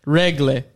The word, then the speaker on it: Höglander: How do you say it? Regle. That's how he says it.